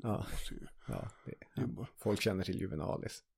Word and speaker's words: Juvenalis: Satiriken Juvenalis, uttalas det så tror jag. – Säkert. – Juvenalis.